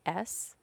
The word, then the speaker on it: Djukas: So now we are in New Guinea with the Yucas, which is spelled D-J-U-K-A-S. Yukas, but pronounced okay. Yukas.